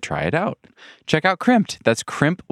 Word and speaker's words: Crimpd: Crimped has you covered. So check it out. Crimped is spelled C-R-I-M-P-D. That's crimp with a D at the end.